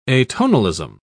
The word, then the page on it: atonalism: British English